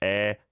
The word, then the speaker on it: 의: 스승 being teacher or mentor, 에, although it's spelled 의, usually pronounced 에, means like apostrophe S.